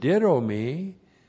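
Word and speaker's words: Didomi (δίδωμι): didomi plus the accusative singular of gnome. The conjunction chi is a connective chi, correctly translated, and. And then we have our friend didomi. D-I-D long O-M-I. Didomi.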